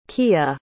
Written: kia

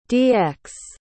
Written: DX

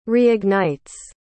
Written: reignites

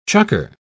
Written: chukker

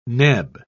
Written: neb

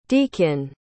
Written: deakin

